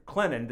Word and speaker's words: Clinton: in Clinton, the T is silent if you're from there, in Clinton, South Carolina.